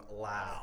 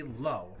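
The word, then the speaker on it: Lowe: And the reason that I don't give him the Rookie of the Year is because he pronounces his name Lau. Okay. – It should be Low.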